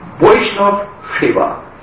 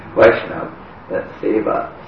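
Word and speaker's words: Vaishnava seva: Vaishnav Shiva. As we say, this is Bengali pronunciation, Vaishnav Shiva.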